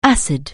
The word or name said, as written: acid